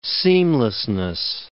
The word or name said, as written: seamlessness